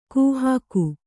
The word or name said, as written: ಕಾಹಿಗ